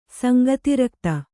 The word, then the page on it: ಸಂಗತಿರಕ್ತ: sangati rakta